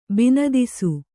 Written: ಬಿನದಿಸು

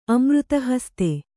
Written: ಅಮೃತಹಸ್ತೆ

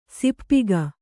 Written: ಸಪ್ಪಗೆ

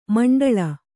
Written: ಮಿಂಡೇಳು

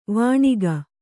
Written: ವೈಣಕ

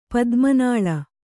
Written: ಪದ್ಮನಾಳ

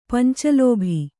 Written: ಪಂಚಲೋಭಿ

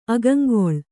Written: ಅಗಂಗೊಳ್

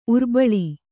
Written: ಉರ್ಬಳಿ

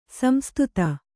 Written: ಸಂಸ್ತುತೆ

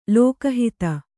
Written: ಲೋಕಹಿತ

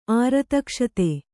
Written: ಆರತಕ್ಷತೆ

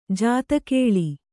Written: ಜಾದುಕಲ್

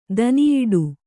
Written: ದನಿಯಿಡು

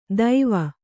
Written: ದೈವ

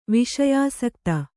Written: ವಿಷಯಾಸಕ್ತೆ

